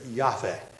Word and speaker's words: Yahweh: It's spelled with just 4 consonants, YHWH, if we transliterate it, Yahweh, if we were to pronounce that.